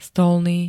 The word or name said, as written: stolný